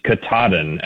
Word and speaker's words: Katahdin: And I'm just going to, off the top, say that the pronunciation is Katahdin, actually. – Katahdin.